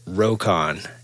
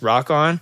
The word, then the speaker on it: Rokon: And then when people saw the name written down, they pronounced it Rokon. And that's how Rokon got its name. It should probably actually be pronounced Rock On because he named it after On the Rocks. But when people see R-O-K-O-N, it looks Russian.